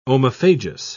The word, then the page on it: omophagous: British English